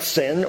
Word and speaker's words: Sin: It's not even the Hebrew letters which makes a TS sound, sin or zin, like with a Z. It's the Wilderness of Sin. That's how you pronounce it.